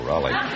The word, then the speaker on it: Roly: No, Raleigh. – You realize, of course, that we can be sued by Abbott and Costello. How do you spell it? – R-O-L-Y. – Oh, Roly. And you pronounce it Roly? – Yes, sir.